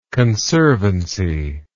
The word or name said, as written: conservant